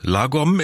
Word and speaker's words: Lagom: Weil Ihr Lieblingswort Lagom ist? Oder wird das richtig ausgesprochen? – Ja, sehr gut.